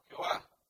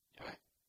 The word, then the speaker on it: Yahvé: Pero al no tener las vocales, como ustedes saben, eh, algunos protestantes más bien lo pronuncian como Jehová. Hoy ya sabemos, teniendo eh, más estudios históricos, que más bien la pronunciación correcta es Yahvé.